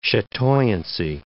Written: chatoyancy

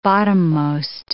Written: bottommost

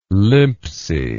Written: limpsy

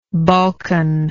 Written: balkan

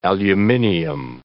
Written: aluminium

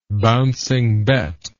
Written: bouncing bet

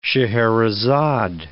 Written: Scheherazade

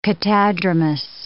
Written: catadromous

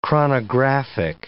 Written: chronographic

